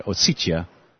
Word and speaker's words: Ossetia: that's how I'm hearing Russians pronounce it, Ossetia,